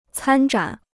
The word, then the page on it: 参展: cān zhǎn